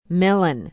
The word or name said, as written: Mellen